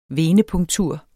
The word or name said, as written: venepunktur